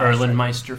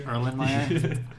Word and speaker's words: Erlenmeyer: Erlenmeister flask. – Erlenmeister? – Erlenmeyer.